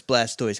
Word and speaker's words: Blastoise: Does that mean his name is Blastus, and we've been saying it wrong the whole time? – No, it's Blastoise, because that's what they say in the anime, and those are all right.